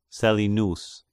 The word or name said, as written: Selionous